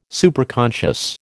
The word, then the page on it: Superconscious: American English